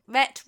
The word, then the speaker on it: Rhett: You have to get his name right sometime. Rhett rhymes with jet.